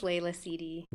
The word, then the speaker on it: Leila Sidi: Leila Sidi. Dang it. Oh my gosh, I was concentrating so hard on CD. – I said it wrong. – I'm sorry. – No worries. I'll just say it. My name is Leila Sidi.